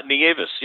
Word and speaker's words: Nieves: Nieves. Yeah, that was Nieves.